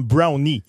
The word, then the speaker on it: Brown: On était là lors de la conférence de presse d'Omar Brown et on l'a présenté comme Brownie. Il y a un employé de l'Impact de Montréal qui nous a dit, en rentrant à la conférence de presse, en passant, on prononce son nom Brownie.